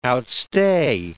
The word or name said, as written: outstay